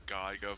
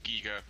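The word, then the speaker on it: Giger: Yeah, I'm getting like a definite Geiger vibe from this, or Giga, rather. I pronounced it properly, I do apologize.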